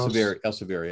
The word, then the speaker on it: Elsevier: To Nature before, and then Elsevier, or how do you pronounce that? That other big publishing house? – Elsevier, yeah.